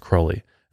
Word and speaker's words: Crowley: And that's because that's just how I've always said him, and I didn't realize that I was saying it incorrectly, but it's actually pronounced Crowley.